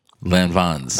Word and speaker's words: Lanvin: How did you say it? – Lanvins. – Lanvins. – That's how you pronounce it.